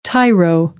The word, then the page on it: tyro: American English